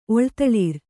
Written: ಒಳ್ದಳಿರ್

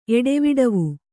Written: ಎಡೆವಿಡವು

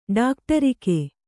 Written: ಡಾಕ್ಟರಿಕೆ

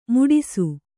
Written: ಮುಡಿಸು

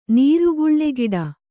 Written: ನೀರುಗುಳ್ಳೆಗಿಡ

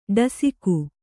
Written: ಠಸಿಕೆ

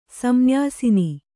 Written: ಸಂನ್ಯಾಸಿನಿ

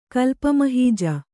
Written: ಕಲ್ಪಮಹೀಜ

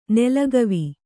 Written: ನೆಲಗವಿ

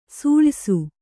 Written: ಸೂಳಯ್ಸು

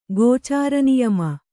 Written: ಗೋಚಾರನಿಯಮ

